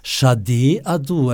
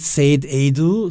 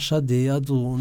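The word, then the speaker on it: Sade Adu: Shade Adu, also nicht Said Adu, sondern Shade Adu.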